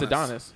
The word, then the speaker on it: Adonis: Like Lil Adonis, how you say his name? – I think no, it's Adonis. His name's Adonis.